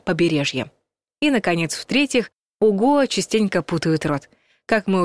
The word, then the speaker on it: Гоа: Во-первых, ударение ставят на «а», хотя все словари единодушно твердят, что правильно произносить «Гоа».